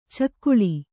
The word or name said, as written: ಚಕ್ಕಳೆ